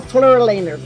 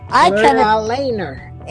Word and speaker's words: Fluralaner: Okay, I'm going to spell Fleura Lehner for you because I said I would. F-L-U-R-A, Fleura, L-A-N-E-R. Fleura, F-L-U-R-A-L-A-N-E-R. Fleura Lehner.